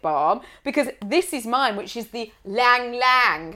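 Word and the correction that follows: ylang-ylang: How do you say? – Ylang ylang. Ylang ylang.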